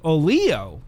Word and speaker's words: Olio: And we got an email from the people at Olio Beard Company. Olio Beard Company. O-L-I-O with an accent on the I.